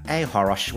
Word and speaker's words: Achoros: At home, but also at Threads at Ahorosh, which is ACHOROS for C is silent.